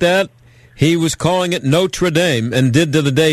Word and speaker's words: Notre Dame: And the only time Leahy actually got a little upset with Beano was because the way he was pronouncing Notre Dame. He said, "Our Lady School is pronounced Notre Dame, lad." And Beano said from that point forward, after Leahy corrected him, he started calling it Notre Dame.